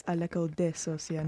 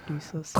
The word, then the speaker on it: l'école de sorcier: Sorry, I said it wrong, actually. It's l'école de sorcier, not du sorcier.